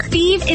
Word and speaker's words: Fives: Feeb is spelled F-I-V-E-S, like the number, but pronounced Feeb.